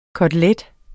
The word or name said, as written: KOTELETTEN